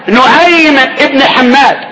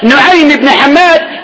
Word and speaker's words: نعيم بن حماد: نعيم بن حماد, هكذا النطق, نعيم بن حماد